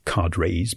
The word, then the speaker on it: cadres: And the local officials, referred to as cadres, sometimes pronounced as cadres by Americans, the local cadres have to whip up the workforce in one drive after the other.